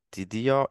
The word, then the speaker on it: Tidio: And uh, Damian is from the company called Tidio, if I pronounce it correctly. Correct. Yes. yes.